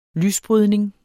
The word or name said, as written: lysbrydning